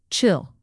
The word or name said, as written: chill